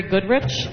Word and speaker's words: Goodrich: I'm Terry Goodrich, and I'm the delegate. The name is spelled T-E-R-R-I, Goodrich, G-O-O-D-R-I-C-H.